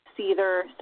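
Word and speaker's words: seiðr: it's pronounced seether, cider, depending on what country you're coming mm-hmm. – from. Cider is Norwegian. And that's my ancestry, so I choose to call it cider.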